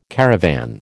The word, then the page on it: caravan: American English